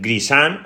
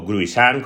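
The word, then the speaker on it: Gruissan: En Grisan, o Gruisan, como se escribe en francés, tenemos la Defi Win.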